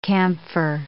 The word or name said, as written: camphor